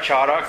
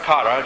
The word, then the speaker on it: chara: Now, the joy that's mentioned here, and by the way, the Greek word is kara, chara, kara, it's pronounced, and it's more than a passing worldly joy.